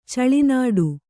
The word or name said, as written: ಚೆಲ್ಲೆನೋಟ